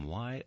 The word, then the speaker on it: Wye: Give a listen to Civilian, Merge Records, the new album from Y Oak, that's spelled W-Y-E, and it might seem like the work of a very large ensemble.